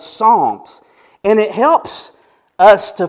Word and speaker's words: Psalms: It's the book of Psalms, which means not palms, as Joe Biden said, but Psalms.